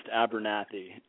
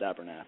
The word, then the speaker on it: Abernethy: One of them keeps the original spelling, which is in E-T-H-Y. The other one changed it so it phonetically sounds more like it's pronounced. So the story goes, so we're Abernethy, but it's actually pronounced Abernathy.